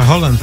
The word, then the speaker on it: Haaland: Y Haaland, pues también se pronuncia Holland también.